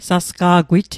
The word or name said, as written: sasqa'gwit